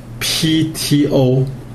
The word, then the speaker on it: Ptolemy: 那南方的王朝呢，就是埃及的王朝呢，它是托勒密王朝啊，它的英文名字是 Ptolemy 啊，它的 P 不发音，所以它叫托勒密啊，托勒密王朝。